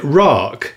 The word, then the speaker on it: RAAC: Although I did hear on the radio this morning, the BBC's education correspondent pronounced it RAAC, which I actually prefer. – As in Dominic Raab.